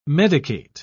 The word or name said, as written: medicate